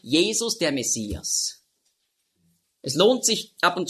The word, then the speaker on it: Messias: Also darum wurde aus Masch, wurde Mess. Und dann Iach mit dem Het am Schluss, Mashiach, hat man früher ausgesprochen, Mashiach. Da hatten sie auch Mühe und darum haben sie daraus gemacht Messias.